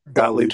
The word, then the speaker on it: Gottlieb: I was the guy that brought up the Doug Gottlieb. I don't know how to pronounce his last name. – Gottlieb.